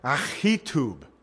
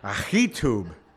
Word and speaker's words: Ahitub: Actually, this is pronounced Ahitub, believe it or not. That's the real Hebrew. So you got to like spit a little on your Bible when you say this. Ahitub.